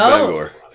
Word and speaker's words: Bangor: They said it correctly, too. – It's okay. – He didn't say Bangor. – It didn't grate on my nerves. – No. This is the first one where they've said it correctly. – Good deal. Well, yep. So no Francis what's-her-name, but correct pronunciation of Bangor.